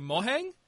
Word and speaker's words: Mojang: And he was like, well, I guess in my language it would be Mohang, but I only ever intended to type it, so it doesn't really matter how you pronounce it.